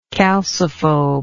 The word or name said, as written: calciphobous